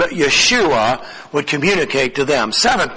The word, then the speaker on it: Yeshua: The word Jesus is a transliteration in the Greek of a Hebrew name, meaning Yahweh saves, and the pronunciation of Jesus is actually Yeshua.